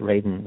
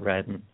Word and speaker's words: Radin: And by the way, it's it's pronounced Raiden, not Radden.